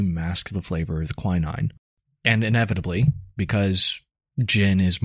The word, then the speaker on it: quinine: It is quinine.